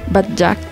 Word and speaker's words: Bagdad: Bagdad.